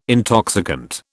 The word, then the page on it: intoxicant: British English